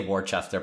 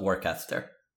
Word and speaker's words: Worcester: I want to say Worcester, but it's spelled Worcester. Just a C, no CH. – Oh, yeah, you're right. Orcester.